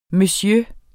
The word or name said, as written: monsieur